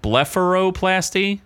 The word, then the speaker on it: blepharoplasty: Blepharoplasty is one of the greatest things I've ever done. – What? What was wrong with her eyelids? – Blepharoplasty, is that the right way to say that? – Sure.